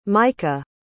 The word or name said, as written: maika